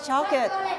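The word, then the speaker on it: chocolate: ภาษาไทยเราเรียกช็อกโกแลตแต่ภาษาอังกฤษเรียกออกเสียงว่าช็อกเกตช็อกเกต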